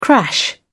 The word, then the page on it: crash: British English